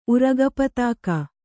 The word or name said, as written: ಉರಗಪತಾಕ